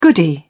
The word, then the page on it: goody: British English